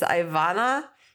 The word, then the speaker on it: Ivana: Now a lot of people don't pronounce the A. They think the A is silent. It's not. It's Ivana. Garcia.